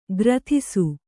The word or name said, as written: ಕೀರ್ತಿಸು